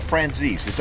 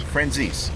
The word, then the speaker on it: Franzese: Which is the more correct? – Actually, that's correct also. It's more the Italian way of saying it. – Okay. – Because it's spelled Z-E-S-E at the end. – Right. – But we Americanize it. It's Francis. Makes it easy. – Okay. – Either way will work.